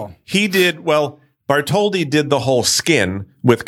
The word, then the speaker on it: Bartholdi: Yes, Bartholdi.